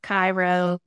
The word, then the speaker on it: Cairo: You'll notice back to the Egypt, we have Cairo, it's pronounced Cairo, we have Karnak, we have Thebes.